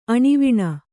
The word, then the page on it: ಆಣೆವಣಿ: āṇevaṇi